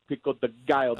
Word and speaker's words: pico de gallo: I forgot about, they're giving you a little pico de gallo. – Oh, yeah. – Did he pronounce that right? – Pretty Pico de Gallo.